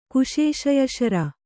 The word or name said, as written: ಕುಶೇಶಯಶರ